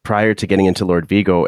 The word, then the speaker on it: Lord Vigo: So your other band, forgive me if I'm pronouncing it wrong. It's Lord Vigo. – Yeah, it's perfect. Right.